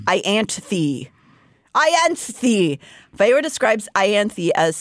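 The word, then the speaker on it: Ianthe: No, in my brain, it's like the I, and then it's like an ant, so it's more Ianthi.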